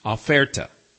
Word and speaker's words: oferta: or the word oferta, which looks and sounds almost like the English word offer, O-F-F-E-R, only in Spanish it's spelled O-F-E-R-T-A, oferta, meaning on sale.